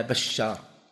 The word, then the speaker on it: بشار: وذلك شبه الآن الحافظ بن حجر رحمه الله في فتح الباري يقول حدثنا بشار بفتح الباء وتشديد الشين بعدها ألف ثم راء، لو أنه قال باء شار بالتشديد ما تنضبط لأنه يعني مع اختلاف النسخ.